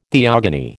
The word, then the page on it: theogony: American English